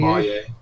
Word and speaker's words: sommelier: I don't know how to pronounce that word, sommelier. – Sommelier.